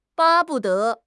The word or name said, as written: 巴不得